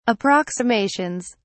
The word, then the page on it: approximations: British English